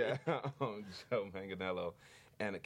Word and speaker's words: Manganiello: Did I say that right? Manganiello. Yep. Joe Manganiello, Matt Bomer. – Oh, it's Manganiello. – Manganiello. – I said it. He was sitting right here where you are, and uh-huh. I said it wrong, and he told me in front.